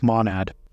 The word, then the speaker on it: Monad: Oh, it's Monad.